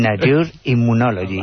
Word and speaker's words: Nature Immunology: Nature Immunology. Oh, vale. – Bueno, seguramente Miguel lo pronunciará mejor. Ya para el 5 sobre 5. – Tú lo has hecho perfecto.